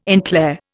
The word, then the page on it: en clair: Indian